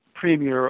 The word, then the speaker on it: premier: It's P-R-E-M-I-E-R. There's no change in that spelling, and it's always pronounced premier. It is not premier or premier or premier, which are just 3 of the many pronunciations of those various words that Americans have.